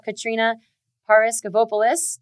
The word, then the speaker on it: Katrina Paraskevopoulos: So everyone listening, we have Katrina Paraskevopoulos. Did I say that right? – Yes. Awesome. Nailed it.